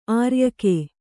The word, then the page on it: ಆರ್ಯಕೆ: āryake